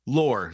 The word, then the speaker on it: LOOR: Is it pronounced lore or lure? – Lore. – Like L-O-R-E. Like folklore. Like folklore.